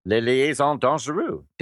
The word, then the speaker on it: Les Liaisons Dangereuses: Les Liaisons Dangereuses.